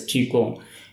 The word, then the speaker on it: Qigong: Normalmente cuando escribimos como Q-I-Chi y G-O-N-G, Kung es la pinyin. Pinyin, uh-huh, que es la manera de pronunciar las letras cuadrados chinos. Hay una manera de decir cómo se pronuncia. Entonces es Qigong.